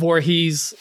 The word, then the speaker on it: Voorhees: Jason Voorhees.